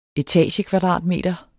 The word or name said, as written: etagekvadratmeter